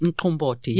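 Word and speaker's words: umqombothi: Mkomboti. – Mkomboti. – Yeah, there you go. Nicely pronounced.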